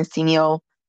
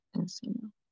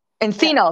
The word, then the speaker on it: Encino: It's a, kind of like an Encino, oh my God, I don't think I'm saying that right. Encino. Anyways, Yeah. – Encino, there it goes.